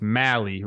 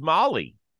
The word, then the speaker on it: Mally: It's Mally, rhymes with rally. So I'm learning all the names for sure. – Yeah, but I thought it was Molly for the longest time.